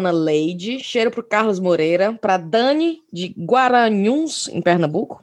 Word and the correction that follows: Garanhuns: Falei certo? É Guaranhuns? É, né? – Garanhuns. Garanhuns. Garanhuns.